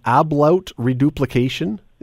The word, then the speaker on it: ablaut reduplication: But apparently this is part of this anyway, is something called ablaut reduplication. I don't even know if I pronounced it right. – Oh, yep, you did.